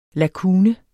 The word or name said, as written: lakune